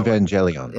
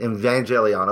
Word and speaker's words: Evangelion: Evangelion. – Evangelion.